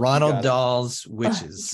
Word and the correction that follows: Roald: There's no N in there. – Oh, I'm sorry. How's it pronounced? – Roald.